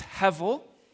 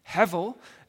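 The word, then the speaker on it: hevel (הבל): The Hebrew word is pronounced hevel, hevel, and that word is translated differently depending on your translation.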